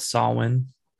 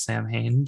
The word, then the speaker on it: Samhain: And like so obviously so it comes from the Celtic festival called Samhain. Um mispronounced by Dr. Loomis in Halloween 2 as Sam Hain, but we're not going to go there.